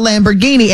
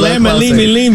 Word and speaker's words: Lamborghini: Yeah, closer than lemon, limi, limi. – Because Lamborghini is 4 syllables. Lamma, lima, lean. You gave 6, baby.